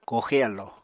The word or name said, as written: kooxe alló